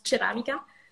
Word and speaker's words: ceramica: Et euh, alors, toi, tu dis céramica mais donc on peut le dire céramica pour les Français. Et euh, en fait, en italien, ça se prononce ceramica.